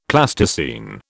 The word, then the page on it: Plasticine: British English